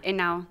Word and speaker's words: Henao: Juana Henao. The H is silent. – Because it's Spanish, Jeff.